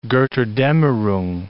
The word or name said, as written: Gotterdammerung